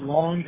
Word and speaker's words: Long: My last name is Long, L-O-N-G.